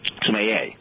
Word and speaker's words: sommelier: She is a, how do you pronounce it, sommelier? – Sommelier.